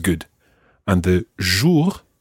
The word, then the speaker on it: bonjour: Now, there are two different sounds in there. And remember last week when we were listening to our nasal sounds, the first syllable is very nasal. It's bon.